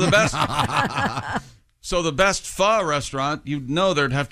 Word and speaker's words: pho: And I just learned that pho, P-H-O, yes. – is pronounced pho.